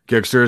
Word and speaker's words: Gigster: Am I pronouncing Gigster right? – Gigster is right.